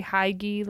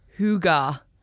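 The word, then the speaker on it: hygge: It's pronounced Hugga. – Hugga. – Hugga. Oh my gosh, I don't like that. I like Huggy better. – I'm calling it Huggy. – Hugga? Okay, but we just made a lot of people happy that are sitting there being like, it's Hugga. – How did you find out it's Hugga? – I said, how do you pronounce Huggy? – And what does it say? – It says H-O-O-G-A-H. – Hugga.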